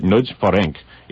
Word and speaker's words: Nagy Ferenc: You put the first last name first and slur them together, I'm told, by a colleague who works at another station who speaks Hungarian. So you'll actually pronounce it Nagy Ferenc. You'll see it in print, F-E-R-E-N-C, last name N-A-G-Y.